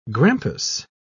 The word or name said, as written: grampus